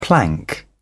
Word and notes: plank: British English

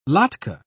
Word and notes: latke: British English

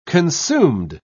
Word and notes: consumed: British English

